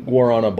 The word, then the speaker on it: Guarana: So what we got is, I don't know how you pronounce it, but it's spelled B-A-W-L-S. – Balls. – Balls. Guarna? – Guarana? Guarana? – G-U-A-R-A-N-A.